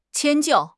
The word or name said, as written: 迁就